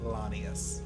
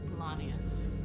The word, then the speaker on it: Laneas: Lanius. – Lanius? – Yeah. – Okay. – L-A-N-E-A-S. – Don't forget it. – Lanius.